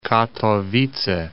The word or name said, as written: Katowice